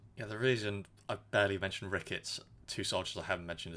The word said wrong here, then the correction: Rickles: It's Rickles.